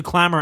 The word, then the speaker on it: Clammr: Download the Clamor app, Clammr. I know, it's spelled weird.